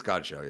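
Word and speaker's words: Godchaux: Is it God? Isn't it Godshow? – Oh, I don't know. Is that wrong? – I think it's Godshow, yeah.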